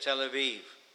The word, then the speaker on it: Tel Aviv: And then he says, I came to them of the captivity at Tel Aviv. In Hebrew, the letter B is pronounced in some circumstances V. Tel Aviv.